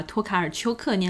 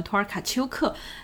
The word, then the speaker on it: 托卡尔丘克: 这个他们赖以生存的森林的一部分，这样的一个故事来自诺贝尔奖获得者波兰作家奥尔加托卡尔丘克的怪诞故事集。 – 当然，这里要跟大家郑重的道歉，在上一集的节目末尾，因为我的口误，把托卡尔丘克念成了托尔卡丘克。